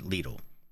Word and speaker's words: Lidl: I always thought it was Lidl, but it's not Lidl. When you go and check out, they say, thank you for shopping at Lidl.